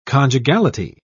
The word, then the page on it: conjugality: British English